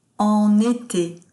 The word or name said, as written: en ete